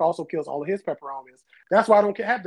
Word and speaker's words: peperomia: Is it peperomias? That's what peperomia. That's right.